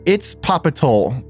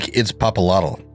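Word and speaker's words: Itzpapalotl: It looks like Itzpopalotl. – Yes, I think the L in the very end is silent. Or at least that's what the YouTube video I watched told me.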